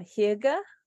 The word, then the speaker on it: hygge: I'm like, oh, actually, no, I think I said it right. Hygge.